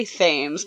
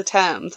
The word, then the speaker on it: Thames: I always say Thames, but I know that's not right. Is it Thames? Thames. It's the Thames.